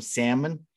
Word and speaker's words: salmon: So yeah, I'd probably do that with some salmon. I, actually yeah, I know people always get on me for pronouncing the L, but it's there.